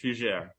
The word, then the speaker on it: Fugere: My last name is French, Fugere. My Fugere.